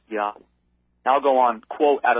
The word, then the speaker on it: Yahweh: They say, quote, the divine name, YHWH in Hebrew characters, was probably pronounced Yahweh.